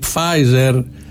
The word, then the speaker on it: Pfizer: Τι Pfizer που κάνει τις δοκιμές της εταιρείας στην άλλη. – Δεν προφέρεται το πι. Ναι, επίτηδες το απάντε. – Σαν τη Μισελ Φάιφερ.